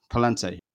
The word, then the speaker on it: Palante: And a big part of that is the song Balante. Palante, she seems to say, you, where's the Spanish expert? How do I pronounce it? – Palante.